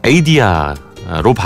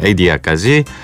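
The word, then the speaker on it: Adia: 아, 에리 크래프트의 레일라 그리고 사라 맥락란의 아, 에이디아로 발음을 하는군요. 네, 에이디아까지 라이브로 두 곡을 들어봤습니다.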